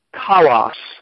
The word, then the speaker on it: kalos: It's translated from the Greek word kalos, and it's spelled or transliterated K-A-L-O-S, kalos. And the word evil is translated from a Greek word, kakos, transliterated K-A-K-O-S.